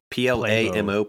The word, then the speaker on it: Plamo: So Bondo's hero name is Playmo or P L A M O Plamo, but yeah. In the Japanese it's pronounced Puramo.